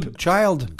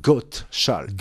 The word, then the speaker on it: Gottschalk: Alors, déjà, il faut le prononcer. Bon, à l'écrire, je le... Gottschalk? – Gottschalk. – Goat? – Goat. G-O-T-T-S-C-H-A-L-K.